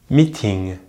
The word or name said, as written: meeting